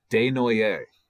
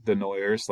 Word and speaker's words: Desnoyers: But also, to that matter, Elliot Desnoyers is evidently how you say his name, not Desnoyers, like we were saying.